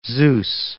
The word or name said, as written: Zeus